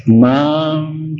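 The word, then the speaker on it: māṁ: The thing is, the long words should be pronounced long, otherwise they will not fit into the lyric. Mam chayo, mam is long. Mam is long. So don't be miser in pronunciation.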